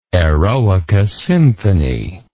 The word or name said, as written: Eroica Symphony